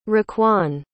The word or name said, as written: raquan